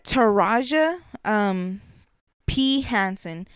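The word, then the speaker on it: Taraji P. Henson: taraji um p henson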